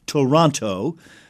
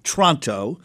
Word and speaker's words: Toronto: And Canadians themselves not only cannot correctly pronounce their largest city, Toronto, which God clearly made with three syllables. They say Tronto.